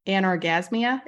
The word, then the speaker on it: anorgasmia: It's anorgasmia. Is that right? – Yeah. – Okay, perfect.